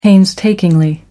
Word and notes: painstakingly: American English

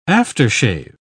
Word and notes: after-shave: British English

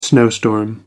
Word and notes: snowstorm: American English

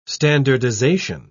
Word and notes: standardisation: British English

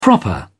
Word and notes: proper: British English

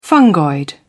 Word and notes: fungoid: British English

